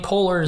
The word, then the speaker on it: Poehler: Amy Poehler is how you say it.